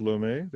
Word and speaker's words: Lomé: Were you, is it pronounced Lomé, the capital? – Yes.